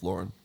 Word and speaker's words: Lauren: Ralph Lauren, yeah. – Lauren. It's pronounced Lauren, Aaron. That's a fact.